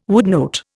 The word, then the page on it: woodnote: Indian